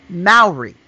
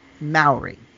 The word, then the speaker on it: Maori: And or she lives in New Zealand, excuse me, and that it is pronounced Maori, not the way I said it. So it's the correct pronunciation is Maori.